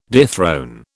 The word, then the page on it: dethrone: British English